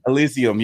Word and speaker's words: Elysium: Valkyrie Asylum, November 11th on PC. I don't know how to say it. Should we say it again? – Elysium.